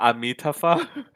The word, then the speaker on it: Amitabha: I butchered the way it's saying. But are you sure it's not Amitabha? – It's similar. It's that one. It's that one. So everybody pronounces it differently in different languages.